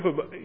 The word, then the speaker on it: Habakkuk: Women's fellowship, they're walking through the book of Habakkuk. Did I say that right? Another name that's available for children. They're walking through the book of Habakkuk.